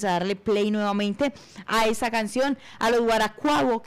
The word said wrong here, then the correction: Guaracuaco: que están sonando. Guaracuaco. Los guaracuaco. Eso, ahí sí lo dije bien.